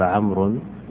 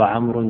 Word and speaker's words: عمرو: قالوا السبب في ذلك عمرو في الكتابة تكتب عمرو وبعدها واو، وهذه الواو زائدة لا تنطق، فخطأ الذي يقرأ عمرو خطأ، إنما الواو هذه وضعت اصطلاحا من أجل التفريق بين عمرو وبين عمر، فإذا وجدت الواو اقرأ عمرو، وإذا لم تجد الواو تقرأها عمر.